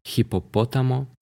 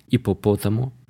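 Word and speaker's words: hipopótamo: Это хипопотамо. Не читается первое, да, но я прочитал, я же дурачок. Ипопотамо — это бегемот.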